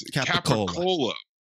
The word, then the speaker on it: capicola: It's one of the many Italian lunch meats, and it's capicola is how you would Americanly pronounce it, but somehow that gets turned into gabagool.